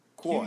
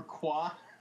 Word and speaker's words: Quoi: It's Q-U-O-I. – Quoy. Q-U-O-I. – Like he's Sequoia. I'm sorry, yeah, he was Sequoia, but it's Quoy. That's what it was. – Or Qua.